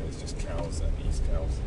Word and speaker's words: Cowes: There's a place called Cowes, not the animal, it's spelled slightly differently, and there's a place called East Cowes.